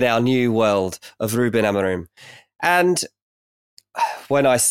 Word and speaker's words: Amorim: Amorim.